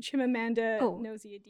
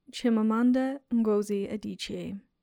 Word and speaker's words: Chimamanda Ngozi Adichie: Chimamanda Ngozi Adichie. – I didn't pronounce that correctly. Her name is Chimamanda Ngozi Adichie, and she is a celebrated Nigerian writer and storyteller, where she talks about the single story.